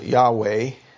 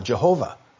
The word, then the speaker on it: Yahweh: And we pronounce it Yahweh. Some translations will pronounce it Jehovah.